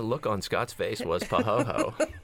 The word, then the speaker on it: pahoehoe: Not pahaha. – Okay, pahoho. It seems too easy. – Pahoho. – P-A-H-O-H-O. – Sorry if I had my bell. P-A-H-O-E-H-O-E.